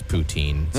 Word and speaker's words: poutine: Is it pronounced poutine or poutin? Yeah. And you said something different, poutine, but well, everyone knows here correctly. In Ontario it's poutine.